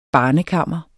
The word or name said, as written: barnekammer